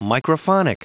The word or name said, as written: microphonic